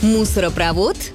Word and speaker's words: мусоропровод: Нередко можно услышать «мусоропровод» или «газопровод», но литературная норма другая – правильно говорить с ударением на последний слог.